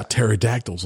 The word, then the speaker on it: pterodactyls: I saw pterodactyls on TMZ. – They have a silent P.